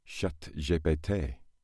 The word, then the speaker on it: ChatGPT: And the reason is because if you say ChatGPT in France, you obviously pronounce it ChatGPT. But said aloud, that sounds exactly like cat, I farted.